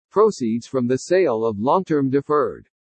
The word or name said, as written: PROCEEDS FROM THE SALE OF LONG-TERM DEFERRED